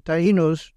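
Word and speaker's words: Taíno: There were two or three tribes, but Tainos, is the way they pronounce it.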